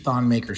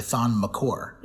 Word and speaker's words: Thon Maker: Every time I see Ron Baker, I think about Howard Beck going on Zach Lowe's podcast after he wrote a huge Thon Maker story. – Yeah. – To say that we've been pronouncing Thon Maker's name wrong the whole time. And it's actually Thon McCore.